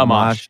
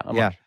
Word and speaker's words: Amash: Amash. Yeah, Amash. – Yeah. Yeah. Was he decent, or was he a possibility? – He's one of the more libertarian congressman in Congress. I am glad that Justin Amash entered the Libertarian Party.